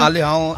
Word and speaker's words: Al Hilal: Al Hilal.